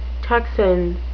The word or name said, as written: tocsin